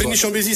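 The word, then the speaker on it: Pregny-Chambésy: Et cette année, outre Vaud-Rue dans le canton de Fribourg, et alors j'espère que je prononce juste Prégny-Chambézy, ça c'est chez toi. – Prégny-Chambézy, c'est bon.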